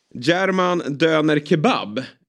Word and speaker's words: German Döner Kebab: Eh, men framförallt då eh, är han sedan i somras ägare av restaurangen German Döner Kebab Uttalade jag det rätt där, Astrid? Det lät helt okej.